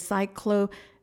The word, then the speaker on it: cyclothymic: Um, and then um, for the the third version of bipolar is, um, and I hope I pronounced it right. I actually had to look up how to pronounce it. It is cyclothymic, cyclothymic.